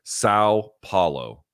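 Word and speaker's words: São Paulo: So my best, again, this is going to be my gringo version, which is only marginally better than yours. Sao Paulo. – Sao Paulo. Yeah.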